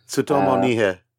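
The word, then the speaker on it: Tsutomu Nihei: So this is volume two of Tsutomu Nai. Apologies for the pronunciation. – Tsutomu Nihe. – Thank you. And this is Opossums, volume two, published by Vertical Comics.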